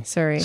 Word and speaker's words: Surrey: Funny because that's how the Canadians say sorry. – Surrey. – Surrey.